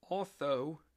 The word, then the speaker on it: Otho: His name is the following: Colonel Otho, that's spelled O-T-H-O, Colonel Otho Holland Williams.